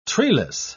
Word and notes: treeless: British English

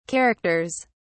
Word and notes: characters: British English

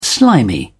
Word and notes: slimy: British English

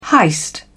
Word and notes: heist: British English

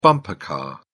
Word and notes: bumper car: British English

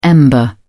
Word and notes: ember: British English

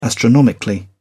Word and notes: astronomically: British English

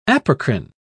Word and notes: apocrine: British English

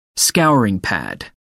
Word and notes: scouring pad: British English

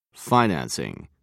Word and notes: financing: British English

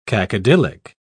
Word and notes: cacodylic: British English

